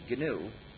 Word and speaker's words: GNU: Anyway, when it's the name of our operating system, don't pronounce it new. You would get people very confused if you pronounce it that way. We've been working on it 20 years now, so it's not new anymore. But it still is and always will be GNU, no matter how many people call it Linux by mistake.